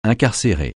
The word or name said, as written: incarcérer